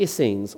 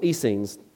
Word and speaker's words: Essenes: And then there's a third group of people called the Essenes, or Essenes, depending on how you want to pronounce the E.